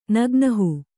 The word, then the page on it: ನಗ್ನಹು: nagnahu